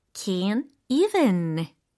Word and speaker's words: can't even: can't even 어, 이 발음을 할 때는요, 여러분, 비음, 그러니까 비강을 열어주셔야 해요. 아시겠죠? 예, 콧소리를 마구마구 내주시면 되겠습니다. Can't even.